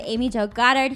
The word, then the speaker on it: Amy Jo Goddard: Today we have Amy Jo Goddard here, who, did I say that right? – Yeah.